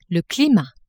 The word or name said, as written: climat